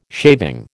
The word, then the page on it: shaving: American English